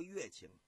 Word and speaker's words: 乐清: 咱书要简言，单说戚继光率领着军队兼程前进，抵达这个温州北面的洛清，这个地方也叫乐清，就是音乐的乐，这是个多音字。